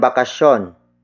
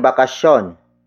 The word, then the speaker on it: bakasyon: Kanilang bakasyon. Kanilang bakasyon.